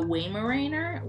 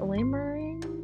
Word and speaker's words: Weimaraner: Oh, how do you say it? Weimaraner? – Yeah, great job. – How do you say it? – I don't know. – I don't know either. – What? Weimaraner.